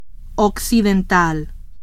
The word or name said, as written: occidental